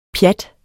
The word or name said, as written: pjat